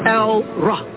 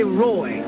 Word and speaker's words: El Roi: El-Roh. Read it. They say Roy. E-L-R-O-I. That's a mispronunciation from the Greek and the Latin of Roh.